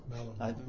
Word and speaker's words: Malinois: Malinois. – Malinois.